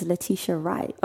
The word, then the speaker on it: Letitia Wright: Hi, my name is Letitia Wright. – Oh, I said it wrong, didn't I? Letitia, not Letitia.